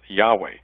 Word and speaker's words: Yahweh: Most scholars believe that the proper way to translate that is Yahweh, or to pronounce it, Yahweh.